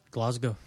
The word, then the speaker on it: Glasgow: He is a guy that is it Glasgow? Is that how you say it? – Glasgow. – Glasgow.